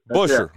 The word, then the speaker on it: Buescher: is it Buescher? How do you say his name? – Buescher. – Yeah. Okay, very good.